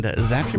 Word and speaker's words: Zapier: And Zapier makes you happier, right, Bryan? – That's correct. – I heard that once, right? It's a fun thing. – Yeah, it helps you pronunciate it correct.